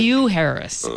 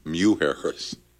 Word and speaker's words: Mujeres: Oh, New Harris, really? – Mew Harris, M-U. – Mew Harris.